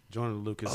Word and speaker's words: Joyner Lucas: It's Joyner Lucas.